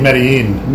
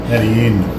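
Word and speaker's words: Medellín: Here's Madonna doing, I'm probably mispronouncing the song, Medellium? – I think Medellin. – Medellin? – Medellin.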